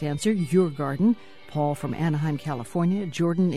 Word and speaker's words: Djurgården: Beautiful day in Stockholm, in the middle of Stockholm, on the Royal Park, pronounced Djurgården.